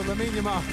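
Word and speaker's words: Lamine Yamal: If I said Lamin Yamal, would that sound right to you? – Yeah, that sounds right. – There we go, Lamin Yamal. That's correct.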